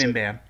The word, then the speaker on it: Mimban: I don't know how to pronounce Mimban. – It. Mimban. Appears in Solo movie.